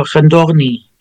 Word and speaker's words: Khndoghni: Knagni. Not even close. Khndoghni? – Oh, Khndoghni. Yes. Yeah, so the transliteration doesn't make much sense. That's not you. That's sort of all those syllables next to one another. You know? It's in English, so it's not you.